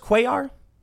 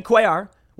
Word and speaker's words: Cuellar: Henry is it pronounced Cuellar? I know the two L's a yes sound. Henry Cuellar was carjacked at gunpoint Monday night near his residence in the Navy Yard area of Washington, D.C., just blocks from the Capitol.